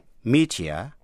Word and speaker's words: meteor: Chữ meteor, M-E-T-E-O-R, có thể đọc làm hai cách, meteor hay là meteor.